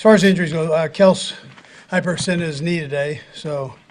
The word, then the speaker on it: Kelce: I do appreciate that Andy Reid uses the proper pronunciation of Travis Kelce. – Kels, hyperextension, we'll see what happens. – Is that one of the old – still, it's one of my favorite moments. Travis Kelce's like, my name's Kels. You guys have been pronouncing it wrong.